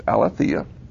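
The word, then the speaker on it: aletheia (ἀλήθεια): It's not pronounced exactly the same, but it's very close. It's aletheia.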